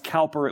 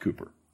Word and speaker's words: Cowper: You're familiar with the name William Cooper, C-O-W-P-E-R. Looks like it would be pronounced Cowper. It was actually pronounced Cooper.